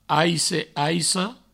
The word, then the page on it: aice, aica: aisse, aisso